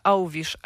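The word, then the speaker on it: Alves: I bardzo wiele się to, e, echem odbiło na, na Twitterze, bo, bo wymawia Auwisz, a jest to osoba, która ma wiele wspólnego z Brazylią, więc zakładam, że tak się wymawia po prostu nazwisko tego Brazylijczyka.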